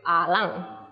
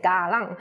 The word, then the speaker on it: Alang: So some people will say Ga Lang, and my people say A Lang, so that's why A Lang in this work.